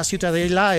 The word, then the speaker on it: Ciutadella: Para mí la Ciutadella es...